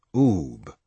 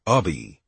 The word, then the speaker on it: Ubbe: UBBE was pronounced Oob, although many, including some lifelong pals, kept saying Ub or Ubby.